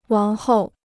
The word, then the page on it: 王后: wáng hòu